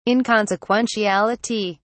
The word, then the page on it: inconsequentiality: British English